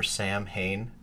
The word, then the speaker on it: Samhain: Okay, so look, quick little trivia about Sam Hain. – Not how you say it. – Not how you're supposed to say it. The Gaelic time of year, the darker half of the year, right, is supposed to be Samhain.